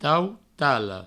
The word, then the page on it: tautala: provençal; tautalo